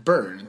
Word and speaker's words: Byrne: Byrne tror jag man uttalar. Ja. Även fast det stavas med Byrne. – Byrne, ja. Men jag tror det uttalas Byrne.